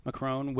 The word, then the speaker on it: Macron: Macron was announced the victor.